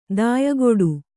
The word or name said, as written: ದಾಯಗೆಡು